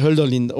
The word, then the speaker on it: Hölderlin: Hölderlin. – Ja, okay. – Ja, habe ich das falsch ausgesprochen? – Nee, absolut richtig, genau, ausgesprochen. Hölderlin.